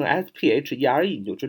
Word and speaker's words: sphere: 这个同样，这个单词啊，lithosphere，sphere，s p h e r e 啊，那么它就是球体、球形这个词根啊，sphere 啊，你看它读 sphere，它拼成 s p h e r e，你就知道 p h 有时候在读音里发啊，发这个发 f 的音啊，就是 h 写成 f，sphere 啊，前面 l i t h o，litho 啊，litho。